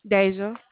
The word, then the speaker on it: Deja: Deja. – Spell that for me, please. – D-E-J-A.